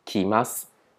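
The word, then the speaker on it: 来ます: 那我们现在看来る的变化，来る呢，它是这个写这样子，那它是要变成 mas 形的时候呢，还要一样也是写同一个汉字来，但是呢，它后面的它的读音呢就改变了，所以呢，它就从来る这个汉字变成改念成きます。所以呢，记住呢，这个汉字有改变读音，这是要特别注意的。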